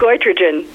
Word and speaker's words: goitrogen: How do you say it? – Goitrogen.